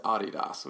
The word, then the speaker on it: Adidas: If you're cool, you would pronounce it Adidas, which is actually the way you're supposed to pronounce it. For those who may not know what I'm saying, it's Adidas, but yeah, you got to pronounce it the right way.